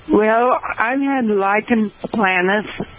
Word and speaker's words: Lichen planus: Lichen planus is how it's pronounced.